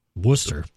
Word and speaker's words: Worcester: Worcester. – Worcester, perfect example.